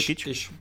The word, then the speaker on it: Kitsch: Čili jsem dozvědav, dokonce se spekulovalo, že Taylor, Taylor Kitsch se jmenuje, myslím, Kit, Kit, jak se to vyslovuje, Kitsch. Kitsch tak ten, ten dokonce má být zvažován jako nějaká náhrada za jako nějakou prostě novou postavu, která tam přijde.